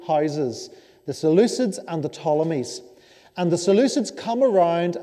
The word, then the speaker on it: Ptolemies: On the left hand side, you have the Ptolemies. So these are the rulers of Egypt, these are the pharaohs. They still use the name Pharaoh. The Ptolemies, spelt with a P.